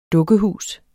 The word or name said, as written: dukkehus